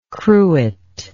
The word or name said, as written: cruet